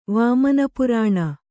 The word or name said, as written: ವಾಮನಪುರಾಣ